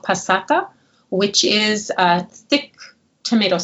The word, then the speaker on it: passata: You mentioned that you always recommend making this with a homemade tomato sauce, which I think you call a passata, if I pronounce that correctly. – So we recommend a homemade tomato sauce. And if you don't have one, then to use passata.